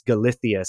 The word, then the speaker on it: Galicia: The pronunciation would be more like Galicia or Galicia. But in Spain, they have that bit of that like that lispy Galicia. Kind of thing. So it's Galicia, Spain.